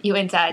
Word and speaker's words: Uinta: And this month we got Uintas Brewing Company Yard Sale Winter Lager. And we're going to go throughout this podcast probably messing up this name, Uinta. – Uinta.